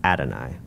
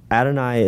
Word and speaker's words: Adonai: Instead, they either referred to it as simply the name, the Hebrew word for which is Hashem, or it's spelled H-A-S-H-E-M, or they used another name for God instead, and that's Adonai, which is spelled A-D-O-N-A-I.